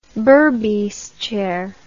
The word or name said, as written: berbice chair